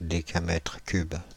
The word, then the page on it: décamètre cube: France (Île-de-France); /de.ka.mɛtʁ kyb/